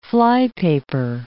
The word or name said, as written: flypaper